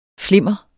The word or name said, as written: flimmer